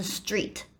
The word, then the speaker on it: street: 呢，或者是它的第二个无声子音会变成有声化，像我们很常见的 street，像那个呃街道 st 的部分的 street 不是念 street，你讲 street 好像就有变成有点像不标准的英文，因为它第二个这个 t s t 这两个无声子音，第二个 t 会变成有声子音的的发音，从 t 变成的。所以是 street，不是 street。